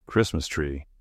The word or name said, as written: Christmas tree